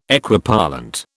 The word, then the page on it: Equipollent: British English